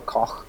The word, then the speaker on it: Koch: Is that how you say it? – Yeah, either Koch or Koch.